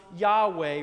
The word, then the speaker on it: Yahweh: Sometimes that name is pronounced Yahweh or gets corrupted as Jehovah.